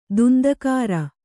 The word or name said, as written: ದುಂದುಗಾರ